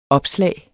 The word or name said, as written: OPSLAGET